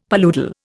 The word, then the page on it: paludal: Indian